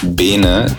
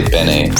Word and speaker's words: Bene: My friends started calling me Bene, just like the Italian word Bene, but people who speak different languages seem to have a problem with pronouncing it that way, so they say Bene, so that's totally fine.